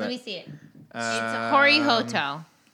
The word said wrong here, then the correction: Hirohito: No, Hirohito.